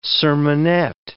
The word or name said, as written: sermonette